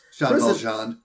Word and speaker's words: Jean Valjean: Jean Valjean. – Oh, is that how you pronounce it? – Yes. Les Miserables.